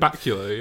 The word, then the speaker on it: Bakula: Bacula, yeah.